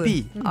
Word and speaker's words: Ebi: $100 voucher to Domino's for the new Ebi Mentaiko pizza. Am I saying that right? – Yes, Ebi.